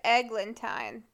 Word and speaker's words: Eglantine: Her name is Eglantine. That's how you pronounce it.